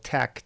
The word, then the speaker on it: TeX: So suddenly, with the advent of computers, and specifically this typesetting program called TEX, T-E-X, where the X was a chi, hence the pronunciation,